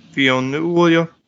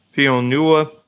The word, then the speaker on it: Fionnuala: And, of course, Bern Gorman, who I really like, Viola Davis, Jason Schwartzman, Peter Dinklage, Fionnuala. Yeah, I think that's how you pronounce it. It's Irish. Fionnuala Flanagan.